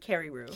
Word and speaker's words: Carreyrou: Carreyrou.